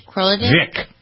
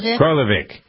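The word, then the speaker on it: Kroleweic: Kroliwiek? You think that's about right? – Isn't the W pronounced V or something like that? – Kroliwiek? – Vik. – Krolovic. – Krolovic. – Vic. Krolovic. Okay, good.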